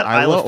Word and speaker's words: Isla: Depends on where you are. I think it's Isla Fisher. Isn't that the actor Isla Fisher? Yeah. – Yeah. So it's kind of like the S is the S is kind of silent. You don't pronounce the slithering of the S.